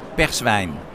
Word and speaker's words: Perswein: Spricht man das so aus? Wie? – Ja, Perswein.